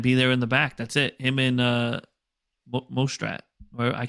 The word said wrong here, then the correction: Mostert: Mostert, Toaster, whatever you want to call him.